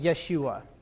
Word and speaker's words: Yeshua: the Hebrew word Joshua is pronounced like Yeshua, okay?